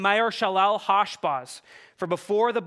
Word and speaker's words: Maher-Shalal-Hash-Baz: But this child would be named Maher-Shalal-Hash-Baz. Yes, that's how you pronounce it.